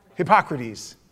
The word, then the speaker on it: Hippocrates: He actually uses medical terms more than all of the writings of hypocrisy. Hippocrates. I said it right the first time.